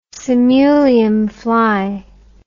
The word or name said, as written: simulium fly